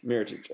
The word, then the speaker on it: Mirotic: So, rumours are he's planning to start Nikola Mirotic. Did I say that right? – Mirotic, okay.